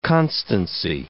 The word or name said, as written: constancy